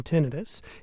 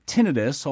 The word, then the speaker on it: tinnitus: An estimated 50 million Americans suffer from tinnitus, including many veterans.